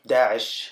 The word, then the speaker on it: Daesh: Just Daesh.